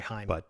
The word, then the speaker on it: Haim: It's actually pronounced Haim, by the way. – Oh, sorry, Haim.